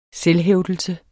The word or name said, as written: selvhævdelse